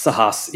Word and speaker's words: Sahas: did I nail that pronunciation or is that off guard? – It's Sahas. You got very close.